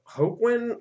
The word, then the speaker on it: Hoechlin: So Tyler Hoechlin, I believe, is how you say it. Does that sound right? – Yep.